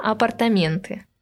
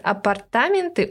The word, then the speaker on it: апартаменты: Даже сейчас у этого слова существуют две нормы. Предпочтительно апартаменты. Вариант «апартаменты» указывается как устаревший вариант.